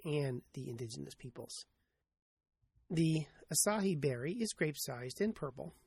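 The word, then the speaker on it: açaí: For some reason, I have been on a spam list that keeps me well supplied for offers to try Asahi berry juice to lose weight. Now, that's spelled A-C-A-I, but evidently pronounced A-S-A-I, with the emphasis on the last syllable.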